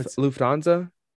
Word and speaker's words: Lufthansa: no, Lufthansa.